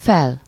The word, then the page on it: up: American English